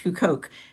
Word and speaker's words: Huqoq: But actually, the last of the lectures, which is on a site, it's got a weird name, HUQOQ. It's pronounced Hukoq.